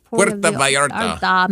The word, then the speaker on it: Puerto Vallarta: Puerto Vallarta.